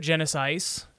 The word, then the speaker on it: Genisys: And then I saw Terminator Genisys, as it is properly pronounced since it's spelled that way, on Hulu or something.